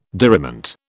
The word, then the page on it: diriment: British English